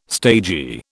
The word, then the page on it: stagy: British English